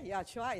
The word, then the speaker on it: Yahshua: Okay, Yahshua is just the Hebrew pronunciation or Hebrew way to call uh, Jesus.